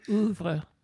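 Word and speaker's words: oeuvre: is it Auvers? Is that how you pronounce that? Ouvert? Ouvre? – Ouvre.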